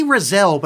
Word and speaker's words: Raziel: But yeah, after all of this, they go to this lake where the other witch, Raziel, is it Razel? How do they say it? – They say Razel, but it's spelled Raziel.